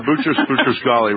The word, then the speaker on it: Boutros-Ghali: let me see if I can pronounce his name, Boutros-Ghali, is that correct? – Yeah, Boutros-Ghali, right.